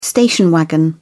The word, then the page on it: station wagon: British English